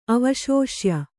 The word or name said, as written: ಅವಶೋಷ್ಯ